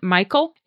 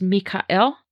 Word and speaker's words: Michael: Most people pronounce it Michael. It's actually pronounced Mikael.